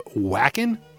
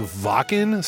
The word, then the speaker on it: Wacken: Although, I used to pronounce that Wacken, you know, the Wacken Festival in 2015. My friend Narnar, he's like, dude, it's pronounced Wacken. Stop being a stupid American.